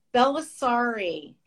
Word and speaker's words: Bellisari: Bellisari.